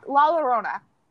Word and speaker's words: La Llorona: Fuck La Llorona.